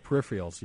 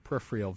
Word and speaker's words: peripheral: Peripheral vision. – I don't think that you just think that word is spelled differently than it is. Say it one more time. – Peripheral. – No, it's peripheral.